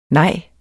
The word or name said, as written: NEJS